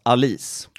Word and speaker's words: Alice: Fast hon heter faktiskt inte Alice. Hon uttalas Alice. – Alice Tegner. – Men det stavas likadant.